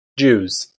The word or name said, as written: Jews